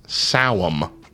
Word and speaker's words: Samhain: Ostara, Lammas, Beltane, Beltane, Beltane, Samhain. I don't know any of so them. So that last one is actually pronounced, despite how it's spelled, it's pronounced Sawum.